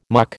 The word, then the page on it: muck: American English